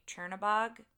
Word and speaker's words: Chernabog: And so the Headless Horseman is in the group with, I never say his name right, Chernabog. – Yes, you said it perfectly.